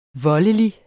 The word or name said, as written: voldelig